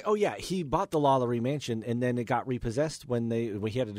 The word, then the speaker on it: LaLaurie: He bought the LaLaurie mansion, but then Is that how you say it? – LaLaurie, yeah. And that's on good authority.